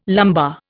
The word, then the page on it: lumbar: Indian